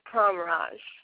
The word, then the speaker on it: Pramraj: My whole name is Sally Tsertsvadze Pramraj.